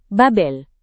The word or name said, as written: Babel